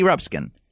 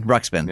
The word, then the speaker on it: Ruxpin: Ruxpin. – Ruxpin. – Yeah. There you go. I've been saying it wrong since I was in the first grade.